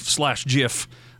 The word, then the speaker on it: GIF: And apparently, Gordon, you would know this, the guy that invented the GIF /JIF, apparently, reportedly, allegedly, rumored to have said that it's pronounced JIF. Even though the G in GIF stands for graphic, so go figure.